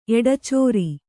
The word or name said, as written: ಎಡಚಾರಿ